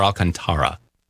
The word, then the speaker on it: Alcantara: Is it Alcantara or Alcantara? – Alcantara. – That's what I figured, because I saw the accent and I actually spent a little bit of time in Mexico.